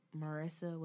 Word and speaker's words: Marisa: That's Marissa with one S and one R. So M-A-R-I-S-A and black like the color.